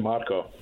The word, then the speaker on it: Marco: You can call me Marco.